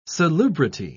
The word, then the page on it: salubrity: British English